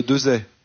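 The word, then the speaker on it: Desaix: Farget avec un X à la fin qu'on ne prononce pas. – C'est bien ça. – C'est comme le général Dezay.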